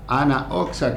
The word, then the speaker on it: Anna Oxa: Anna Oxa, que se escribe Anna WN, Oxa con X.